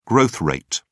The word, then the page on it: growth rate: British English